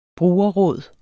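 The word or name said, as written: brugerråd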